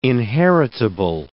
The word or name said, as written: inheritable